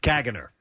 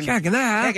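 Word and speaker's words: Caganer: Kaganer. Okay, so that's the pronunciation. – Kaganer.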